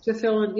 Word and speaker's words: tefillin: tefillin. Is that how you say it? – Yes, tefillin.